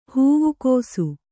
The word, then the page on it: ಹೂವುಕೋಸು: Kannada